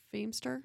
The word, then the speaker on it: Feemster: Fimster. – Oh, Fortune Feemster? – Yes, that's exactly what I just said.